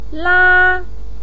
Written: la1

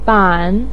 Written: ban3

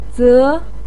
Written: ze2

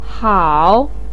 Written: hao3

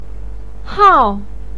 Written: hao4

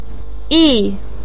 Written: yi4

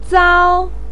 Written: zao1

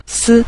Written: si5

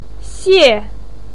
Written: xie4

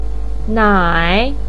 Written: nai3

